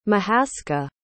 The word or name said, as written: mahaskah